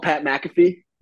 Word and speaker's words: Pat McAfee: Oh, Pat McAfee? – McAfee.